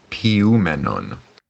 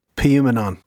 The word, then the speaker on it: poioumenon: And just just so we don't have to take word for it, also got in touch to uh, confirm the pronunciation of this word. poioumenon. – poioumenon. Da da da da da. – poioumenon.